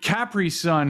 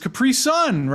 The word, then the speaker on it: Capri Sun: We pronounce it Capri Sun here. Capri Sun is sending me. No, but it's Capri Sun, right? Like Capri that was Sun. That's what the commercials always told me.